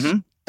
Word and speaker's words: Toit: I think it's some French word, but all of us Bangaloreans. Yeah. – Please. Here it's... Yeah. Sona has decreed that it is toit.